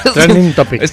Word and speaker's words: trending topic: Para ver si había alguna cosa de la que pudimos hablar, y está de trendy topic, ¿lo dije bien? – Trending topics.